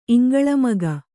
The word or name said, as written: ಇಂಗಳಮಗ